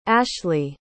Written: ashlie

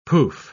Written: poof

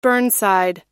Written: burnside

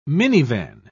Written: minivan

